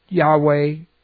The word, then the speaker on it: Yahweh: This one, Lord, is the Tetragrammaton, generally pronounced Yahweh, Jehovah.